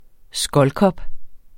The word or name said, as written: skoldkopper